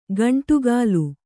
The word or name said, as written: ಗುಂಡಕಲ್ಲು